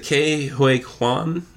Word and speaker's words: Ke Huy Quan: Is it Ke Huy Quan? Is that correct? – Yeah.